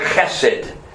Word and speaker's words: chesed: In Hebrew, it's the word chesed. Chesed, you got to say it right. You got to kind of clear your throat at the beginning there.